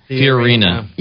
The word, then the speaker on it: Fiorina: Fiorina.